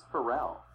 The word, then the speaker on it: Pharrell: I think it's Pharrell. – Oh, good, I got it, wow. – Well, now you know. – Yeah. – It's funny, but you know what, if you said Pharrell Williams, I would know who you're talking about.